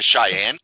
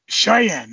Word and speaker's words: Cheyenne: And then in 57, they introduced at issue number 7, and help me with pronunciation here, Corey, Cheney Kid, if that sounds right. – The Cheyenne Kid? – Cheyenne, that's it. See, I don't even know this guy. Yes, Cheyenne, Cheyenne. – Yep, the Cheyenne, Wyoming.